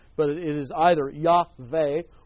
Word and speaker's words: Yahweh: because sometimes it's Yahweh and sometimes it's Jahweh. But remember that there is no such term, there is no such sound as J in the Hebrew language. It's the Hebrew letter Yod, pronounced like a Y.